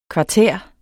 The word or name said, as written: kvartær